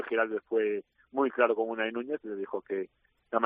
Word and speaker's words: Unai Núñez: para hablar de Unai Núñez, ahora lo he dicho bien.